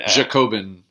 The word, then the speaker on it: Jacobin: Jacobin? – Jacobin.